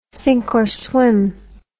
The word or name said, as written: sink or swim